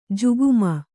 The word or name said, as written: ಜುಗುಮ